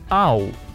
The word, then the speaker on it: owl: Пишется O-W-L. Читается Ау. Внезапно. and he looked out her picture window and saw for the first time in his life an owl. Почти аул, только ударение на а.